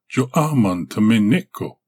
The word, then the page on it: jo-aman-tmeneko: Galindri